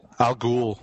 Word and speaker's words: Al Ghul: Al Ghul.